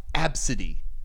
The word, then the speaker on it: Abcde: Seriously, that she spells it A, B, C, D, E, but it's pronounced Absidy.